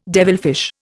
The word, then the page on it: devilfish: Indian